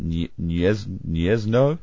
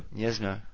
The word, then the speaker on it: Gniezno: Is it Niez Niezno? Gniezno. There you go.